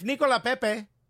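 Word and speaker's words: Nicolas Pépé: But if Nicola Pepe, because that's how we pronounce his name now,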